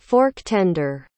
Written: fork-tender

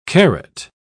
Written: carat

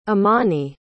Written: amani

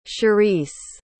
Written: cherese

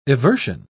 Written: eversion

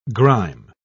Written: grime

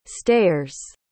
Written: stayers

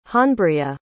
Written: honbria

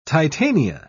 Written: Titania

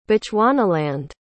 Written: bechuanaland

